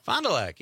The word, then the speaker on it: Fond du Lac: Green Bay, Rhinelander, and I've got one coming up in, and I'm going to say it wrong, and everybody out there is going to judge me. Fond du Lac? – Fond du Lac, yeah.